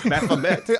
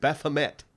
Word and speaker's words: Baphomet: I always pronounce it Baphomet. It's Baphomet. – I've never even heard it before. – I've seen it. I've seen the name, yeah. – You've seen it. – Baphomet. – Yeah. – Okay. – Yeah. – That's the correct pronunciation of it.